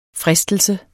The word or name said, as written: FRISTELSEN